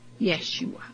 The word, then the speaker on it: Yeshua: There is no J in the Hebrew language, so it is actually Yeshua, which itself is a corrupt pronunciation of the sacred name of the Son.